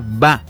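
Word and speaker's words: ba (八): Y en mandarín, en el idioma mandarín, el número 8 se pronuncia ba, por lo que la fecha suena baba, idéntico a la palabra utilizada para decir papá.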